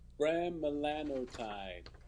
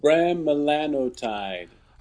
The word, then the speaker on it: Bremelanotide: Bremelanotide. – Wikipedia, can you say that again a little louder? – Bremelanotide.